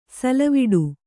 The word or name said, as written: ಸಲ್ಲವಿಡು